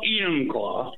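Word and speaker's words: Enumclaw: And welcome back, in Emmonclaw, Washington. Am I saying that right? – Well, Emmonclaw.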